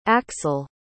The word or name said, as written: axl